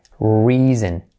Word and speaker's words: reason: Reason, reason. Esa se escribe con E y se suena con I. Reason, that's the reason. Y la S es reason.